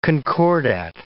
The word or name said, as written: concordat